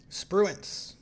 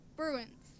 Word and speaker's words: Spruance: Spruance. – Okay, that. Spruance.